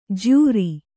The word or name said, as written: ಜ್ಯೂರಿ